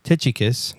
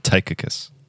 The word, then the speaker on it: Tychicus: Secondly, because Tychicus. – No. – How do you say it? – Tychicus.